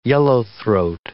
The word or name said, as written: yellowthroat